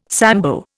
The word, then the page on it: Sambo: Indian